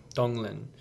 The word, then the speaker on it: Tonglen: So I'm not sure again if this is the same tradition, but, and I may pronounce it incorrectly, Tonglen? – Donglen.